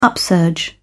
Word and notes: upsurge: British English

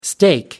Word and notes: steak: American English

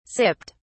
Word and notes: sipped: British English

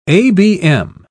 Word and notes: abm: British English

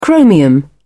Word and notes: chromium: British English

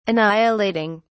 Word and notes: annihilating: British English

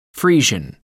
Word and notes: friesian: American English

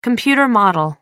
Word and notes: computer model: American English

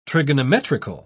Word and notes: trigonometrical: British English

